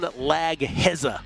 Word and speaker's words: Laghezza: John Legeza, everybody. – Thank you so much, my man. – And I miss you, too. John Legeza, if you want to spell his last name phonetically. L-A-G-H-E-Z-Z-A, MLB writer for The Athletic.